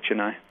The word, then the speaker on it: Chennai: And then you land, is it pronounced Chennai, if I'm saying that right? – Yeah, basically Chennai.